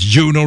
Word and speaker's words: Juno: And they are Uno Records UK, Uno Records in London, England. And Uno is spelled J-U-N-O, just like it sounds.